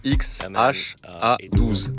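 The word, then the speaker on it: X Æ A-12: How do you say the name? – I mean, it's just X, the letter X. The AE is like... pronounced Ash. – Le prénom se prononce X-H-A-12. – A12 is my contribution.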